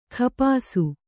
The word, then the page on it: ಖಪಾಸು: khapāsu